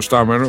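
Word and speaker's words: Starmer: λέγεται με την προφορά του Στάρμερ, όπως μου είπε ο Χατζινόπουλος, έφυγε και πάει τώρα για πρωθυπουργός.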